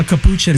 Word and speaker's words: capuchin: A capuchin.